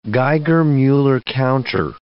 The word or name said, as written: Geiger-Muller counter